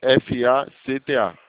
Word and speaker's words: FACTA: É FACTA. O C é mudo.